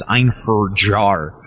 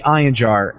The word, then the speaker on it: Einherjar: On our first Limit Breaking News segment, or on one of our shows, I can't remember, we pronounced Einjar as Einherjar, which is very incorrect and very Midwestern USA way to say it. So it's actually Einjar, and I have to thank Arc Cipher from Titan who sent that in.